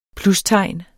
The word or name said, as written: plustegn